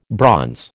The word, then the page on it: bronze: American English